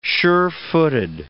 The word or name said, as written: sure-footed